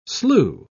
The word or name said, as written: slew